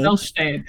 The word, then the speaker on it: Milchtein: Milchtein.